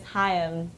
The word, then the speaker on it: Haim: Hey, how you pronounce our last name is Haim. Two syllables. There you go.